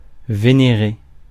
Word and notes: adore: American English

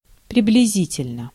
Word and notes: roughly: IPA: /ˈɹʌf.li/

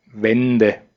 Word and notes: Wände: /ˈvɛndə/

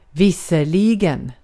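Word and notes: visserligen: Swedish

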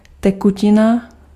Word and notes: tekutina: /tɛkʊcɪna/

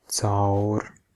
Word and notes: through: /θɹu/; /θɹuː/

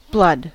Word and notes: blood: /blʌd/

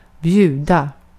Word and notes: bid: American English; [ˈbɪd]

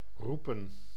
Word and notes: roepen: [ɾu.pǝː]

